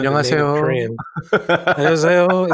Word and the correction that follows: 안녕하세요: is that how it is? – 안녕하세요.